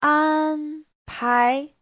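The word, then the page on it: 安排: an1pai2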